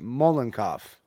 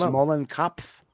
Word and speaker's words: Mollenkopf: It's pronounced Mollenkopf. – I've seen some videos like that. – Isn't it pronounced Mollenkopf? That sounds like a German name. – Sounds right, yeah. – Mollenkopf. Kopf meaning head.